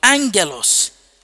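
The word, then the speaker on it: angelos (ἄγγελος): Parangelo is spelled P-A-R-A-G-G-E-L-O. P-A-R-A-G-G-E-L-O. Parangelo. And it's from the root word angelos. Amen. Angelos is the word used for angels. A-G-G-E-L-O-S. Angelos is the word used for angels.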